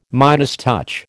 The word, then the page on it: Midas touch: American English